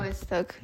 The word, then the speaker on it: Białystok: Białystok? How do you say it? – Białystok. – Białystok. – Yeah, you're good.